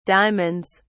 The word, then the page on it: diamonds: British English